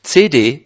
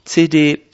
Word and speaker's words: CD: Und ich möchte euch bitten, kritisch dieser CD oder CD zuzuhören. In der Schweiz ist das die Betonung auf der letzten Silbe.